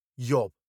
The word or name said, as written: yob